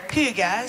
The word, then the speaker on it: hygge: Hygge.